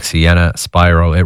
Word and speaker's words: Sienna Spyro: Sienna Spyro, it rhymes with Byro.